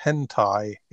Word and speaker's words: hentai: I guess it's pronounced hentai. H e n t a i.